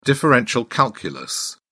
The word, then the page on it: differential calculus: British English